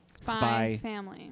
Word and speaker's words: Spy X Family: We're talking about Spy Family.